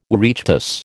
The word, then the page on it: Oireachtas: American English